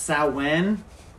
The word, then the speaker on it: Samhain: So Samhain, that's how it's spelled. – That's weird because... – But it's pronounced Samhain.